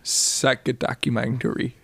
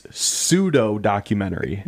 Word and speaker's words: pseudo-documentary: The real way to pronounce this for everybody listening and what he's trying to say is pseudo-documentary. Okay.